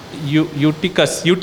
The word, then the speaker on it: Eutychus: And the ninth one, it's the beautiful, you know, and Eutychus, that's how you pronounce.